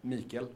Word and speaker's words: Mikkel: Mikkel.